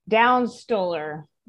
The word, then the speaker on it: Downs-Stoler: Downs Stoller.